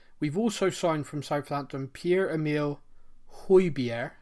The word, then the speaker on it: Pierre-Emile Højbjerg: Pierre-Emile Højbjerg. – There we go. Perfect. – Yeah. So I've had that. I've studied it. – Studied it. I love it. – Pierre-Emile Højbjerg.